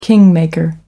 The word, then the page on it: kingmaker: American English